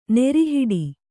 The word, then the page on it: ನೆರಿಹಿಡಿ: neri hiḍi; Kannada